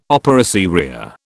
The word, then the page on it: opera seria: British English